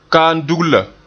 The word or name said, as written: kaam dugla